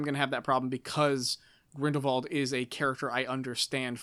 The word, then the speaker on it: Grindelwald: And though and like those like that, even saying Grindelwald, you say it with a V, like it's pronounced with a v.